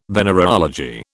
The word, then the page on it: venereology: British English